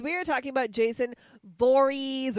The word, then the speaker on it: Voorhees: Oh, that is not how he pronounces his name. According to his mother, it's Voorhees. – I think it's it depends on where you're from. – She says Voorhees.